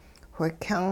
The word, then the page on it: whikyung: Hupa